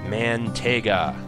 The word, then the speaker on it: Mantega: Mantega, that's what her name is actually pronounced.